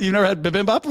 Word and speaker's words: bibimbap: I went to the Googles. Let's see what they say. Bibimbap. Bibimbap. Bibimbap. You got it. You nailed it. Bibimbap.